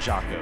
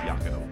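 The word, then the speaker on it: Jaco: I'm going to leave you here with something from Yako Gardner, which is, I'm pretty sure you say his name, not Jocko, I believe it's Yako.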